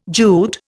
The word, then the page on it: Jute: Indian